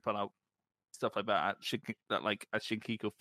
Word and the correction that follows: Shinjuku: face. Shit, I can't say that properly. – Shows, Shinjuku face.